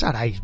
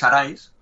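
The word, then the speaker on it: Arise: Bueno, perdón, ¿es Aris o Arais? Porque me estoy dando cuenta que el que lo digo mal soy yo, es Arais, ¿verdad? – Bueno, es, en inglés, el título se trojo, es Arais, pero lo puedes pronunciar como quieras.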